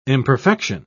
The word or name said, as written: imperfection